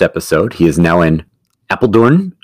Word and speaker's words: Apeldoorn: James coming to us from Apeldoorn. Am I saying it right? – Yeah, Apeldoorn. Brand new office.